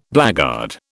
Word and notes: blackguard: British English

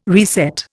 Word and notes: reset: Indian

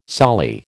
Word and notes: soli: American English

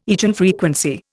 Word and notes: eigenfrequency: Indian

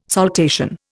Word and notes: saltation: Indian

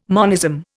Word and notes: monism: Indian

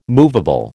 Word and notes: movable: American English

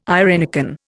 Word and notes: irenicon: Indian

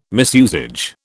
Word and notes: misusage: British English

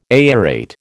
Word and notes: aerate: American English

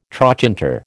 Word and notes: trochanter: American English

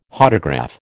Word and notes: Hodograph: American English

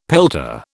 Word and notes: pelta: British English

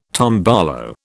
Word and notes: tombolo: British English

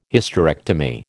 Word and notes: Hysterectomy: American English

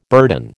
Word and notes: burden: American English

